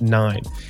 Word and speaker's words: KNIME: Now, Nime, it's spelled K-N-I-M-E, but it's pronounced Nime.